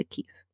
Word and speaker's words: kief: Like that's the keef. I can't describe it any better. But it's spelled K-I-E-F.